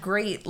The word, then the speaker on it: Grate: Nope. It's spelled G-R-A-T-E. – So like grate for cheese. – Yeah, but like it sounds like great, like, woohoo, you're so great.